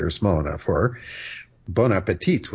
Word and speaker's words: bon appétit: Now, because the T at the end, that last T, has no E after it, so that last T is not pronounced bon appétit, but a lot of waiters don't know any better, and they say bon appétit.